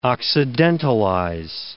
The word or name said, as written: occidentalize